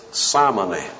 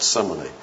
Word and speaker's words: simony: Now, We know from church history there is such a thing as simony. Some people pronounce it simony. Simony.